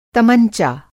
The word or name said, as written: तमंचा